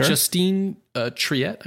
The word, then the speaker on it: Justine Triet: Justine Triet, I believe is how you pronounce it.